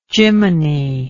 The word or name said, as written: jiminy